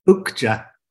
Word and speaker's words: Okja: Okja. Thank you. – Okja. Fantastic. Okja. I think I said that right. Okja. – Okja. Like the oak tree. – Right. – With a J at the end.